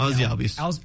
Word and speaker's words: Albies: And I got Ozzie Albies. Is that how you say it? – Yeah, Ozzie Albies.